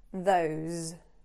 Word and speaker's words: those: Don't end it with a s sound. So it's not those, which is actually quite hard to say. It's those. Those.